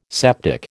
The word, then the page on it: septic: American English